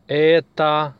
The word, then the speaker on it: это: Это работа. Это книга, etc. If we want to say this for neuter noun, we say это. Это. We spell это, but pronounce это, because о is not stressed.